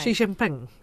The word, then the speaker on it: Xi Jinping: Which I didn't realise, because Winnie the Pooh was a caricature of old the Chinese leader, who I don't want to mispronounce his name. – Xi Jinping. – Yeah. Oh, there you go. Oh, yeah, that's great.